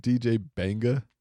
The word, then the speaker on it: Mbenga: DJ Mbenga was bad. Kwame Brown was bad. – You know it's just DJ Benga? – It's not Mbenga. No. It's the M is silent.